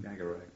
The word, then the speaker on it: Skagerrak: the Cataract and the Skagerrak. Well, you can pronounce it better than I do. You know what I mean, where I mean. – Skagerrak. – Skagerrak and Cataract.